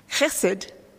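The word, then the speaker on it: chesed (חסד): And it's pronounced chesed. And when I taught Hebrew, I found that many Australians have a difficulty going the ch sound. So it's loch if you're Scottish. It's definitely loch.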